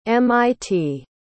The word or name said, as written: mit